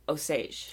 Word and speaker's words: Osage: Actually, everywhere I listen to podcasts, they call the county, which is named after the tribe, Osage. – Osage. – I believe, yeah. – That sounds right. That sounds right. So that's the way we're going to pronounce it.